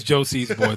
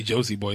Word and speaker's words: Josie's Boy: It's Josie's Boy. Thank you. Please say my name correctly. I hate when people say Josie Boy. It's like, no. It's plural constantly.